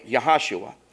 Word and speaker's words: Jehoshua: But it would have been pronounced with a Y, Yahashua. There is no J in the Jewish language. Did you know that? When you see a J, it's always pronounced like a Y.